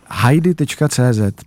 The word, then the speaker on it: Heidi: Heidi se to ale vyslovuje, jako Heidi domů, tak Heidi Janku. – Ne, jako Heidi Klum.